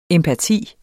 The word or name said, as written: empati